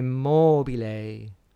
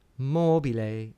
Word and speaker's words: mobile: So think about la donna mobile, mobile. The accent is on the third last syllable.